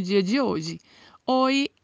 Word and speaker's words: Hoy: Hoy significa hoje, assim como no português o H não tem pronúncia no espanhol.